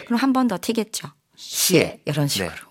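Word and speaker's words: 시에시에 (谢谢): 대부분 이렇게 발음합니다. 세세니. – 세세니. 근데 이거는 성조가 없는 거야. – 그리고 저는 학생들이 세세니 하면 대답 안 해요. 세세는 없어요, 중국어. – 세세는 발음이. – 없는데 보통 네. 이거 우리가 이게 건너 건너 듣다 보니까 네네. 세세라고 들리는데 사실은 정확하게 시에시에.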